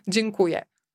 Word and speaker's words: Dziękuję: Dziękuję.